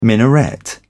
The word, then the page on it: minaret: British English